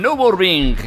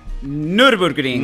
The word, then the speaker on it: Nürburgring: que es el Nürburgring. ¿Lo he dicho bien hoy? Nürburgring. Nürburgring. – mira, es, muy fácil. Tú di Nürburg. – Green. Nürburg.